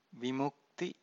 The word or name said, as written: vimukti